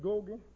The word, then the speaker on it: Ngugi: My name is Richard Gogi. The last one is spelled N-G-U-G-I.